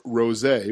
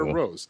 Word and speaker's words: rosé: Each month, there are new delicious wines, like the insanely popular Summer Water Rosé, which I found out from being on Wrestling Night in America. That's how you pronounce rosé. It's not Summer Water Rose. So it's Summer Water Rosé.